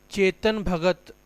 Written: Chetan Bhagat

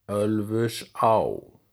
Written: Ölfusá

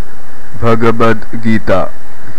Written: Bhagavad Gita

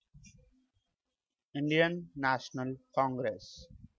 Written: Indian National Congress

